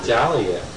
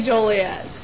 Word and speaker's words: Joliet: Some people call it with the L. Joliet. – Like, Jolly old St. Nick. – Pronounce the O as an A.